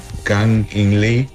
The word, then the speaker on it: Kang In Lee: Se llama Kang In Lee.